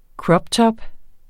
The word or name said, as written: croptop